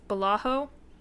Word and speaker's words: Balaho: So they found their homeworld of Balaho. I think that's how you say it. – Yep, that sounds right.